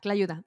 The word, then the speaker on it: tlayuda: Oh my gosh, that tailuda, am I saying that right? – Yeah, tlayuda. Mm-hmm.